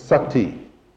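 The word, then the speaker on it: sati (सती): The Sanskrit word for to be, the root is sat, S-A-T. The feminine form, put a long I on the end of it, and you pronounce it sati.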